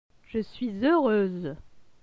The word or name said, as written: Je suis heureuse